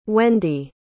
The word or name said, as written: wendy